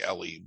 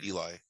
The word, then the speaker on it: Eli: They say it like Ellie, but it's Eli.